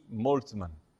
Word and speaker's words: Moltmann: Jürgen Moltmann se escreve Jürgen, J-U-R-G-E-N. E Moltmann, né? M-O-L-T-M-A-N-N. T-M-A-N-N, Moltmann.